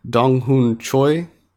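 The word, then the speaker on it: Dong-hoon Choi: It's by director Dong Hoon Choi.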